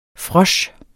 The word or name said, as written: frosch